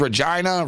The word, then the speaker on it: Regina: Except, as our boy Mick Aussie knows, Regina is not Regina. And, like, the best way of putting it is Regina rhymes with vagina.